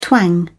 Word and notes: twang: British English

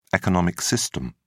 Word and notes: economic system: British English

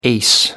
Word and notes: ace: American English

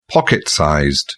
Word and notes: pocket-sized: British English

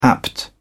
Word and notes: apt: British English

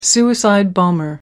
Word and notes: suicide bomber: American English

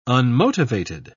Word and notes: unmotivated: British English

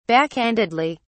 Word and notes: backhandedly: British English

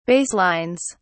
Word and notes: baselines: British English

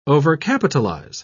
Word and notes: overcapitalize: British English

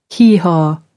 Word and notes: hee-haw: American English

